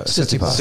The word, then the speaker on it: Tsitsipas: Uh, Tsitsipas.